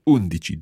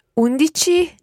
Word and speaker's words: undici: Okay, so all of those numbers so far use that dici ending. And the stress goes on whichever syllable comes before the dici ending. Undici, dodici, tredici, quattordici, quindici, sedici.